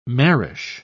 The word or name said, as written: marish